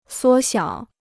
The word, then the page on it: 缩小: suō xiǎo